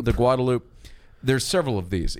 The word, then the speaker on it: Guadalupe: If you take the Guadalupe, if you use the proper pronunciation, it can be rocky at times.